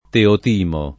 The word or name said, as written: Teotimo